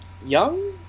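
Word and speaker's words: Jung: It was co-written by Simon Pegg and Doug Young? Is that how you say it? I believe so.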